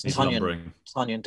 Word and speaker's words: Tonyan: And Robert Tonyan rhymes with onion.